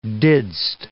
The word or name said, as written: didst